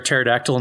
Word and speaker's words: pterodactyl: So Joey, you know why you can't hear a pterodactyl in the bathroom? – I already know the punchline to this one, but yes, go on. Silent. – The P is silent.